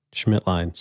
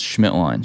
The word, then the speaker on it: Schmidtlein: Schmidtlein. – Simple. – Simple. – But then I go, is it Schmidtlein? But I'm like, well, who the fuck says lean? So it's Schmidtlein.